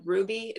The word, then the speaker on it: Rouxbe: So I found online an online culinary school called Ruby. It's spelled R-O-U-X-B-E.